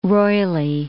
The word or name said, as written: roily